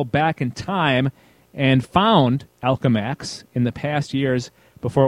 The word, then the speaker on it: Alchemax: I think it's Alchemax. – Okay, Alchemax. – Like as an alchemist.